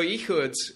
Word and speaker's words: Ehud: So Ehud, Ehud is more like a CIA assassin, right?